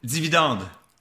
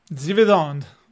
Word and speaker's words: Dividende: How do you say dividends? – Dividende. – Dividende. Okay. – Dividende. See, it's not that difficult, right? – I do better with Spanish.